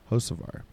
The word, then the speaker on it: Hocevar: And then Carson Hocevar. Am I saying that right? Yep. Nailed it. Hocevar.